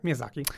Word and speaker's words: Miyazaki: Yeah, Miyazaki.